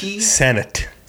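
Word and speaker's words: Sennott: It's S-E-N-N-O-T-T. – Senet.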